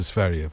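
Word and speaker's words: Sverige: No, Sverre is the right, correct pronunciation.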